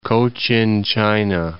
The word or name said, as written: Cochin China